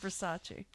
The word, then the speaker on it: Versace: It's a Versace. It's actually pronounced Versace.